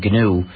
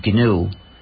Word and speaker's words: GNU: So the G is pronounced. – Yes, of course. – You've heard it here. – If you called it the new operating system, you'd get people very confused.